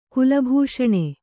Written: ಕುಲಭೂಷಣ

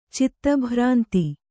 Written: ಚಿತ್ತಭ್ರಾಂತಿ

